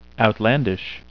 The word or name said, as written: outlandish